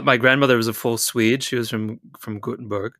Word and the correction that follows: Gothenburg: The way that I've been told, it's Gothenburg. Gothenburg. – Right, Gothenburg. Yeah.